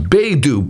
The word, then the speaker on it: Baidu: I think it's pronounced Baidu, B-A-I-D-U. It's Chinese.